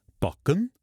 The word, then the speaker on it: Bokken: Cleo har en lille rød lædertaske, der hedder Bokken. Ja, det skal udtales på norsk, parentes bemærket, fra hendes diskotid i Italien.